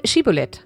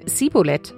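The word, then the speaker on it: Schibbolet: Wenn er das verneinte, forderten sie ihn auf: Sag doch einmal Schibolet, Wasserschwall. Sagte er dann Sibolet, weil er es nicht richtig aussprechen konnte, packten sie ihn und machten ihn an Ort und Stelle nieder.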